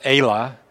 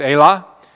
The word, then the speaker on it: Elah: You see the Elah, or the Israelis pronounce that Elah. They call this the Valley of Elah. Elah is how we would probably say it.